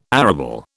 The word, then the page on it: arable: British English